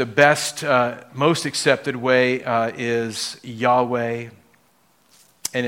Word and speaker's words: Yahweh: And so he says, tell them I am sent me to you, and then gave Moses this name, YHWH, which we pronounce as Yahweh. There's another way to pronounce it. It's called Jehovah. That's where you take the YHWH, you take the vowel sounds from the Hebrew word Lord, Adonai, and add the vowel sounds in, and it gives you this Jehovah, or you say Jehovah. But that's a made-up way of saying it because you're just adding the vowels from another word for Lord.